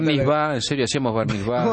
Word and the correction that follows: bar mitzvah: ¿Cómo pronuncia Barnizba? – Barnizba. – No, no, Bar mitzvah.